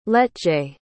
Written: letje

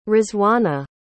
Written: rizwana

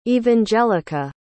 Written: evangelica

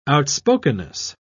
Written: outspokenness

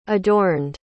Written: adorned

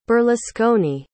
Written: berlusconi

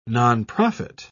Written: nonprofit

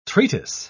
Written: treatise

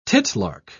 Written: titlark

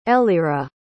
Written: eliezra

